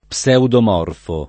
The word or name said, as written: pseudomorfo